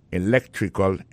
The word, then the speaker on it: electrical: Electrical engineering. Ça veut dire accent tombé dans electrical là, tombé sur deuxième syllabe là. Electrical engineering. C'est sur deuxième syllabe là. Elect là. Plus appuyé.